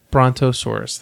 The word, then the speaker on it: Brontosaurus: Which was Bronchosaurus. I can't pronounce it. – Bronto. – Brontosaurus, thank you.